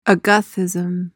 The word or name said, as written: agathism